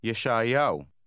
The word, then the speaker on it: ישעיהו: ישעיהו. ישעיהו. – Be careful to try to pronounce it just as the speaker does.